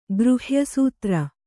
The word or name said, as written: ಗೃಹ್ಯಸೂತ್ರ